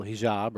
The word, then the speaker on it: hijab: It's kind of like imagine a woman, you know, in the Middle East in a full hijab, right?